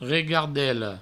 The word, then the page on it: regardela: regardello